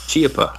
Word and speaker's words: Chiappa: Chiappa, I believe, is the correct pronunciation.